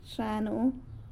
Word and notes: Xa nuu: sanlucasquiavini

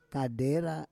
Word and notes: kadera: Roon

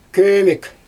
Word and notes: kömek: Xyzyl

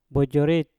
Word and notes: bojorit: mpur

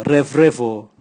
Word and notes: revrevo: Raga

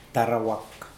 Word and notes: derwak: Mokilese

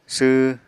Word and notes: sw: apatani

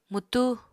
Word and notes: mwtú: Apatani

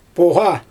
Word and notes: poGa: Xyzyl

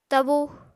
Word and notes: tabu: Apatani

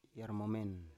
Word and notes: yarmomen: Biak

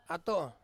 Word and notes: ato: Ansus

